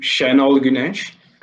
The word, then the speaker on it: Şenol Güneş: Is it Gunesh? Gunesh? – I don't know how to say his name. Is we call him Şenol Güneş.